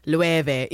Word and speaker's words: Loewe: I don't know what goes on there, but I know it's pronounced Loewe, even though it looks like it should totally not be pronounced that way.